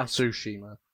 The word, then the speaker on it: Tsushima: But then you have, well, for me, 2020, Ghost of Tsushima, which I... – Tsushima.